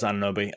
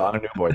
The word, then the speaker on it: Anunoby: I, by the way, I know it's Anunoby. I stuck in my head that it's just like that, the mispronunciation of his name. – You can also call it Anunoby though. Either one is technically correct.